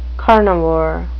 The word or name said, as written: carnivore